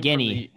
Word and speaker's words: Guinea: I always pronounce this wrong. The 100 Guinea Cup until a team Guinea. – Guinea. – Yeah, it's like a guinea pig.